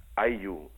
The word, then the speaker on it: Aylu: Aiu. Aiu se escribe A-Y-L-U. – A-Y-L-U.